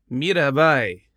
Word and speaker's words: Mirabai: Oh, how do you pronounce this? Hold on, is it Mirabai? Hold on, let me look into this. Say it again. – Mirabai. – All right, so it is.